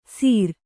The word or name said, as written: ಸಿರಾ